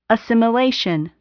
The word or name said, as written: assimilation